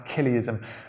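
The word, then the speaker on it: Chiliasm: It's often referred to as Killeism, spelt with a CH, but it's pronounced Killeism.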